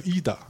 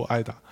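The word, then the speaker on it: Ida: 那提名的包括呃艾达修女艾达，包呃那个好像是读成伊达啊，然后我一开始都都读艾达。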